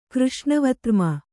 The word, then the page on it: ಕೃಷ್ಣವತ್ರ್ಮ: křṣṇa vartma